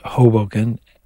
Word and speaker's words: Hoboken: That's the way you pronounce it, Hoboken.